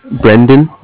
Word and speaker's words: Brendan: Brendan, B-R-E-N-D-A-N.